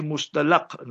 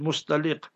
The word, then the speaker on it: Mustaliq: So Bani Mustaliq, our people pronounce this also incorrectly, Mustalaq now. The Asah and Afsah is Mustaliq.